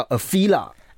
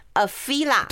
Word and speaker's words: Afeela: 它这个念起来叫 Afeela。 – Afeela，对对，它这个名字其实有点意思，我待会再解释哦。